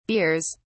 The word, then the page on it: beers: British English